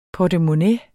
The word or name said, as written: portemonnæ